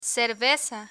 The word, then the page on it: cerveza: spanish